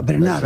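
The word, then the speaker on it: Bernhardt: Sara Bernhard, entonces. Sara Bernhard. – Todo el mundo dice Sara. – Sí, pero tienes que pronunciar. – Pero Francia, ¿cómo la llamamos? – Tienes que pronunciar a la francesa, Bernard.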